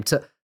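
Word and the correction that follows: Taulia: Tagovailoa. – Talia. – There you go. Talia. You got it.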